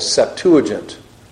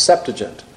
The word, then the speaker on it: Septuagint: the Septuagint, or sometimes pronounced Septuagint.